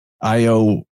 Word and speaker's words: Io: Io would be pronounced like yo, and the A and the ah sound are easily interchanged between languages.